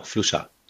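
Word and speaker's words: Fluxà: Fluchard? – Genau, Fluchard.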